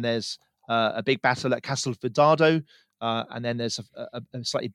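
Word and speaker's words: Castelfidardo: I'm glad you said Castel Fidardo, because I wouldn't I've been miles off with my pronunciation of that.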